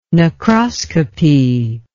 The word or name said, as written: necroscopy